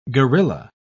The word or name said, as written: guerilla